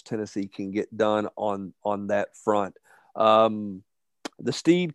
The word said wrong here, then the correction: Sneed: kid from down in Florida. To say his name right? – Sneed, I'm sorry.